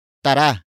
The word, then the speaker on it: tara: Word for word it's tara, walwal.